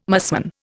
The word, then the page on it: musmon: Indian